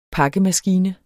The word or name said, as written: pakkemaskine